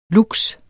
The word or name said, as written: lux